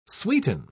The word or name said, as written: sweeten